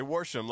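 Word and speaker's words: Worsham: I say Warsham, like a redneck says, Warsham dishes.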